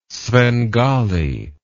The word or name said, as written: svengali